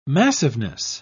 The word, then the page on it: massiveness: British English